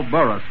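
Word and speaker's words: Burrus: The program comes to you, sponsored and transcribed by the Burris Mill and Elevator Company, makers of good old light crust flour. By the way, let me spell Burris for you.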